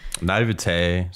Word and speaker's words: naivete: How do you say it? Naivete? – Naivete. Naivete. Like you're it just sounds so pretentious. I know it's like croissant.